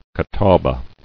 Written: Catawbas

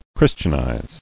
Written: Christianize

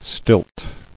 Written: Stilting

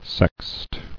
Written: Sext

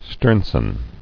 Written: Sternson

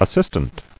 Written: assistant

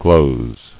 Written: Glozed

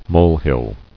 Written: Molehill

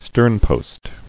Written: Sternpost

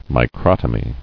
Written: Microtomy

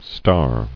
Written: Starring

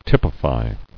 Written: Typifying

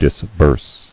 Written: Disbursing

